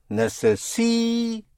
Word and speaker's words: necesito: So again here, on the penultimate syllable. Necesito.